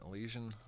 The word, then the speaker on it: Elysian: Elysian? – Sure.